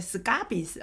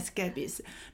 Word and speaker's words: scabies: 都听过疥疮的大名，非常可怕，哈，叫 scabies，好，那有时候他们发音会变 scabies，哈，但是其实是念 scabies。